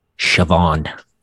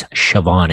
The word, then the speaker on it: Siobhan: Siobhan is how that name's pronounced. It's Siobhan. It's an Irish name. – Really? – Oh. – Yep. – A silent B? – Siobhan. – That's Gaelic, baby.